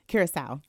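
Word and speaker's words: Curaçao: have a certificate uh, from the University of Netherlands Antilles in Caracas. Um, did I pronounce that correctly? – Curacao. Curacao. Yes.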